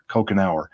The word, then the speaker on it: Kochenour: It's pronounced like drinking a Coke every hour, Kochenour, but it's not at all.